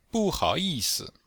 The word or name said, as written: bu4hao3yi4si5